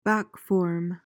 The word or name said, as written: back-form